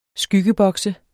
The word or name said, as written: skyggebokse